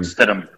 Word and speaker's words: Stidham: Most notably, Jamal Adams' interception of, how do you say that guy's name? Stidham? Stidham? – Jared Stidham.